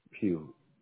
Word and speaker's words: Pugh: Pugh. – Can you spell it, please? – P-U-G-H.